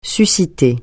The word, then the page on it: susciter: French